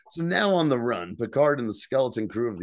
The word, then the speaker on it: Picard: I like that Vadic pronounces Picard correctly with no D.